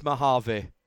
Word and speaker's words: Mojave: So yeah, and I'm told it's Mojave, is how you say it.